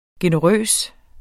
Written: generøs

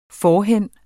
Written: forhen

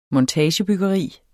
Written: montagebyggeri

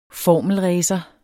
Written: formelracer

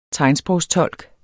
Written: tegnsprogstolk

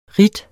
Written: ridt

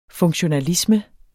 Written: funktionalisme

